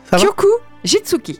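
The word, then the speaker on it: Kyokujitsuki: Donc, euh, le Kyoku euh, Tsuki. Kyoku Jitsuki. Jitsuki, voilà. Je savais que j'allais écorcher.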